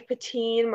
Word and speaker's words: Puttin: You can follow me on Twitter at Olivia Patin. My last name is spelled P-U-T-T-I-N.